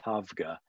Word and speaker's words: Tabgha: It's spelled T-A-B-G-H-A, but it's pronounced Tavga.